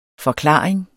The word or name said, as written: forklaring